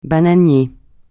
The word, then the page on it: bananier: francais